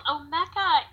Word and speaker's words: Omeka: So what is Omeka? Is that the way you pronounce it? – It is, mm-hmm. Yeah. So, Omeka is actually a Swahili word that means to display or lay out wares, to speak out, to spread out, to unpack.